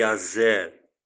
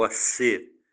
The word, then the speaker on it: Aser: Esses foram os filhos de Israel: Ruben, Simeão, Levi, Judá, Issacar, Zebulon, Dan, José, Benjamim, Naftali, Gader, Gade e Aser, ou Asser, como alguns pronunciam em Israel, ou Asher, como alguns pronunciam também.